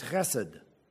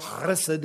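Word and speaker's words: chesed (חֶסֶד): You'll notice that it's for forever is his chesed. And you can say it with the guttural chesed if you'd like, or you can just say chesed.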